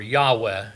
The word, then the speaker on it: Yahweh: The name Lord, as you and I know, is the King James way of translating Jehovah, and or Yahweh, as you would pronounce it in the Hebrew, closer to the Hebrew.